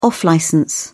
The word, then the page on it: off-licence: British English